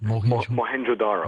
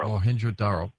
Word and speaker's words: Mohenjo-Daro: Mohenjo-Daro. – Mohenjo-Daro.